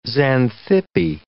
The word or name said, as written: Xanthippe